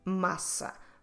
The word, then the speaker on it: massa: So in Portuguese, pasta is massa. Massa also means dough. So dough and pasta, massa. And massa, we spell it with a double S. So we need to pronounce it as a S. Massa.